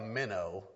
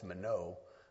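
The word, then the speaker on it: mino (μένω): It looks like minnow, but it's pronounced mino.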